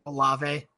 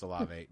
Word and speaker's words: Olave: Is it Olave? – Olave. – Amazing. Yeah. All right. I'm glad I got that right. I think they're going to take Chris Olave.